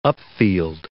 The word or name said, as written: upfield